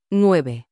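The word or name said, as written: nueve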